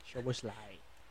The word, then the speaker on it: Szoboszlai: Terus Liverpool dapat pemain yang bukan terlalu pemain top cuman sangat ini ya, sangat sangat baik menurut gue nih McAllister sama Szoboszlai. Itu enggak tahu bacanya tuh. – Szoboszlai.